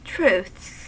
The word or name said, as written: truths